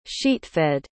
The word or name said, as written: sheetfed